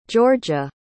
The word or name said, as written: giorgia